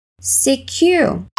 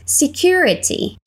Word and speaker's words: security: Security. Security. Security. – Dependendo do sotaque da pessoa, de onde ela é ou como ela prefere falar, você pode pronunciar segurança dando ênfase ao T ou não.